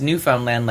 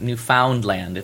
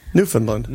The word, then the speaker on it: Newfoundland: But it's you pronounce Newfoundland like understand Newfoundland like that's how you pronounce it. It's not Newfoundland. It's Newfoundland. – You brace it, but you say like understand.